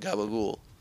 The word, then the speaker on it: gabagool: And that's what gabagool is. That's just how they pronounce it in this very small sect of, like, New Jersey. They call it gabagool.